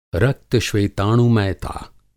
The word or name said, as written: रक्त-श्वेताणुमयता